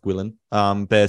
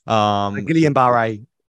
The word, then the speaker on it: Guillain-Barré: I think it's pronounced Guillain-Barré syndrome. – Guillain-Barré. – Guillain-Barré, there you go.